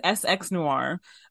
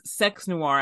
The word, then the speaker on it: SX Noir: Well, my name is SX Noir. But it is Sex Noir. I took the E out of sex because of censorship.